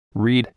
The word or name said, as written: read